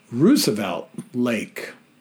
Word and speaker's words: Roosevelt Lake: I was impressed to hear Kevin pronounce Roosevelt Lake correctly as Rue, not Rose.